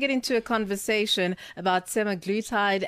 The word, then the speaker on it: semaglutide: You said it right, semaglutide. But it is a bit of a tongue twister.